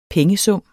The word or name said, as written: pengesum